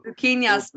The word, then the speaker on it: Luquinhas: Luquinhas. – Luquinhas, player of the week.